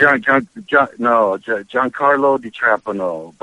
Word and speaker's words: Giancarlo DiTrapano: Is that how you pronounce Giancarlo DiTrapano.